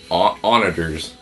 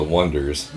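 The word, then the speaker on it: Oneders: How would you pronounce that? Oneaters or Wonders? O-N-E... Mm. D-E-R-S. – Probably Onders. – Onders. Onaders. – Onaders. – Onaders is probably how I would pronounce it. Either way, we're wrong. Because it's clearly the Wonders.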